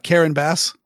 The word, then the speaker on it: Karen Bass: Are you sure that's how it's pronounced? It's not Bass. – I mean, no. Yes, it is. That is how it's pronounced because I have heard her say her name.